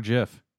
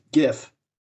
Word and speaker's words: GIF: It's GIF. – Because it's a G. – You get the hard G, not the soft G. – If it was Jif, it'd be like Jif peanut butter.